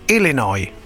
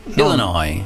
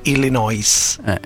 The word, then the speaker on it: Illinois: Ecco, ripetilo bene il primo, che l'Illinois non si legge la S finale, tutti no. – sbagliano, invece si dice Illinois. – Illinois. – Non Illinois.